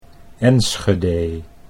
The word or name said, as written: Enschede